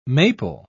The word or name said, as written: maple